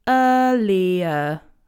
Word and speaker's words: earlier: In this word, in general British English, we don't pronounce any of the letters R. So we don't say earlier, okay? That will be more American.